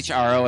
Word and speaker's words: Aro: It's Aro, and everyone is really hesitant, but they always say it right. It's just like the letter R and the letter O. So Aro, yeah.